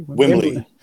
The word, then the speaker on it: Wembley: Wim, Wimbled Wimbled Wimble Wembley? – Wembley. Wembley. Okay, yeah.